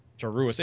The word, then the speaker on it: Joruus: Joruis. It's and that's very it's very explicit because there's two U's in his name, and that comes up.